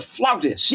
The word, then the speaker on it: flautist: You pronounced the word correctly, and even on classical music stations wherever I am, they're not pronouncing it this way anymore. You just spoke of a person who plays the flute as a flautist. – Yes. – One doesn't hear that pronunciation much anymore, but it's the accurate one, and you just gave it to me.